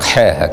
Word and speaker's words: حا: تنطق حا هكذا على الراجح كما نص على ذلك أبو عمرو بن الصلاح في مقدمة علوم الحديث. بعض المغاربة كانوا يحبوا يقولوها تحول، لكن المشارقة يقولوا حا وهي أفضل،